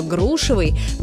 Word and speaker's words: грушевый: Но вернемся к грушам и запомним, что ударение во всех формах прилагательного «грушевый» падает на первый слог, как в слове «груша».